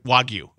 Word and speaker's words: Wagyu: Is it Wagyu? Am I saying it right? – Wagyu. – Wagyu.